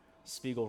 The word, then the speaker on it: Spiegel: Spiegel rhymes with Smeagol.